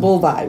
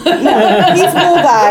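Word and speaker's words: Buliwyf: It's pronounced Bulvai, which is not how it's spelled. – In my head, he was Beowulf. – No, he's Bulvai.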